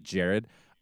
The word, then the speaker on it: Gerard: And then Doug Ray Scott playing, you know, her husband, Jared, which I love the fact that his name is spelled G-E-R-A-R-D, but he pronounces it Jared.